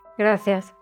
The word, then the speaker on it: gracias: Keep an ear out for the accent from Spain, where the C that sounds like an S is pronounced with a TH sound. So gracias sounds like gracias.